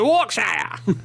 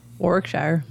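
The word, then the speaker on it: Warwickshire: They say Warkshire! – Warkshire.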